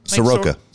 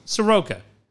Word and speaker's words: Soroka: Sorka going down. – So who's your World Series prediction now? – or Sirica. How do you say that kid's name? – Soroka. – Soroka.